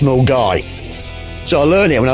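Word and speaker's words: Milngavie: But any self-respecting Scotsman, anyone who spent any time in Glasgow, will know that Milne Garvey is pronounced Mulgai.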